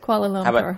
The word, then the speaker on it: Kuala Lumpur: Kuala Lumpur.